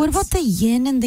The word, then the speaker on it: yin: Also, thank you for saying it right. Yin, yang. A lot of people say ying. – I know. – I only learned that about 6 months ago. It's not ying. – No, it's yin and yin.